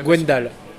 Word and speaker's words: Gwendal: Gwendal.